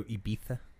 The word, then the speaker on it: Ibiza: Did you go to Ibiza? That's how you say it.